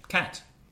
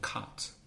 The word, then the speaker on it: cat: No, your pronunciation is somewhere in the middle of my cat and cut. – Yeah, I'd say so. And that's, I think, a regional variation because I come from Belfast.